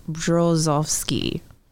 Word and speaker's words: Brzozowski: And the only way to pronounce it is with a whole lot of practice. It's Brzozowski.